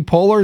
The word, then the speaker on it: Poehler: Amy Poehler. Is that how you say it? – Oh, yes.